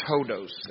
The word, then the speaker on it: hodos: In Greek, this word is hodos. It's spelled H-O-D-O-S.